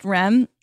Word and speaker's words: REM: that it's pronounced rem like it's you know rapid eye eye movement yeah. section of sleep. – Okay, like it's obviously R E M, but like they've gotten sloppy and just they're calling it REM, which I find humiliating.